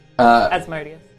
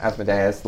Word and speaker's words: Asmodeus: How do you pronounce it? – Asmodeus. – Yeah, Asmodeus. Some would pronounce it Asmodeus. Like, it's... – Yeah, but that's how I... – There doesn't seem to be a true consensus. – Yeah. – Both are generally accepted.